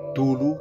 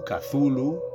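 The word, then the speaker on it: Cthulhu: El origen en la perdida ciudad submarina de Riley del monstruoso semidios Tulhu, que algunos pronuncian Tulu, Cazulu, en fin, hay para todos los gustos.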